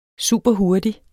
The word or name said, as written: superhurtig